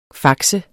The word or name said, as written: fax